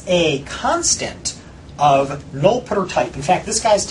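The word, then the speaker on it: nullptr: And I deliberately pronounce it null putter to make it sound different than null pointer.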